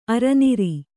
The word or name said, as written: ಅರನಾರಿ